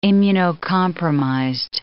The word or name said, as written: immunocompromised